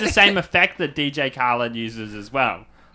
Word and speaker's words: Khaled: I like to call myself the DJ Carlhead of hyper-specific home renovation shows. – I don't know who that is. DJ Khaled. – Have I been saying it wrong this whole time? – You don't know?